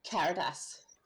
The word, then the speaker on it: Caritas: It's actually Caritas.